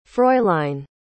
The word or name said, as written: fräulein